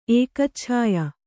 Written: ಏಕಚ್ಛಾಯ

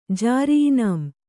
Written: ಜಾರಿಇನಾಂ

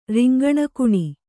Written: ರಿಂಗಣಗುಣಿ

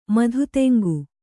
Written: ಮಧುತೆಂಗು

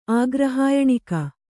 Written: ಆಗ್ರಹಾಯಣಿಕ

